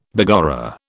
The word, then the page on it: begorra: British English